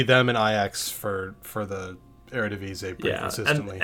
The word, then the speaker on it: Eredivisie: Yeah, the Eredivisie.